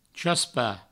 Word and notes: chaspar: chaspa; provençal

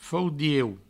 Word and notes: faudiu: faudieu; Provençal

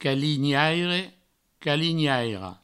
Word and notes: calinhaire: provençal; calinhaira; calignaire; calignairo

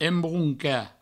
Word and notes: embroncar: embrounca

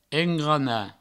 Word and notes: engranar: engrana

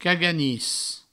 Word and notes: caganis: cago-nis

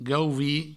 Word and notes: gauvir: gauvi

